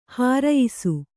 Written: ಹಿರಿಯಿಸು

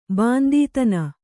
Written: ಬಾಂದೀತನ

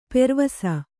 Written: ಪೆರ್ವಸ

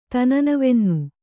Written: ತನನವೆನ್ನು

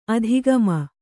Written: ಅಧೋಗಾಮಿ

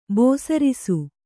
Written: ಬೇಸರಿಸು

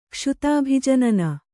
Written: ಕ್ಷುಧಾಭಿಜನನ

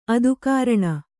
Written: ಆದಿಕಾರಣ

